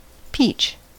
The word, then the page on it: peach: American English